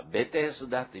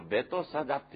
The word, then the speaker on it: ベテスダ: エルサレムのヘブライ語でベトサダと呼ばれるという、これは司法の表紙にの絵引用しました絵にはベテスダというふうになっています。このベトサダというのは読み方の違いのようですね。この新共同訳だけがベトサダというふうに読んでるんです。